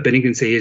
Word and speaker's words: benignity: Just in case you're wondering, I had to go and check with my father, the English teacher, how to pronounce benignity. But benignity is the quality of benign kindness.